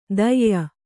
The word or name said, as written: ದಯ್ಯ